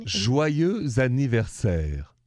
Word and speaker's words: joyeux anniversaire: Joyeux anniversaire. – Joyeux anniversaire.